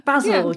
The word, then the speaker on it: Basil: Is it Basil, like the man's name? – I said Basil, yeah. – Basil, yeah.